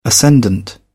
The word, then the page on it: ascendant: British English